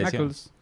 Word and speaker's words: Knuckles: Knuckles.